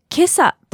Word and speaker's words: 今朝: この 2 つの漢字で this morning という意味になるんですけれども、なぜかこれで今朝と読むんですよね。